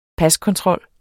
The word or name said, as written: paskontrol